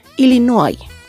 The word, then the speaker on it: Illinois: Noise se escribe exactamente igual que en español, pero se pronuncia de manera muy distinta debido a su origen francés. Escritura Illinois, pronunciación Illinois.